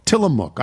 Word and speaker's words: Tillamook: The mook part is pronounced like cook, Tillamook.